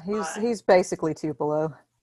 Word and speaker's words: Tupelo: So the movie was shot in Tupelo. Am I saying that correctly? – You are.